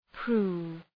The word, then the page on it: prove: pru:v